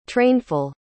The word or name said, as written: trainful